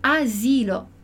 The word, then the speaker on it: asilo: Então, a gente não vai escrever a palavra com dois S's, mas vai pronunciar como se tivessem dois S's ali, ok? Então, esse é o caso, a situação, onde a gente tem um S entre duas vogais, mas que se pronuncia com som de S e não com som de Z, como em asilo, ok?